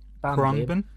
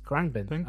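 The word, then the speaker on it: Khruangbin: Crangbin. – Yes, Crangbin and Leon Bridges.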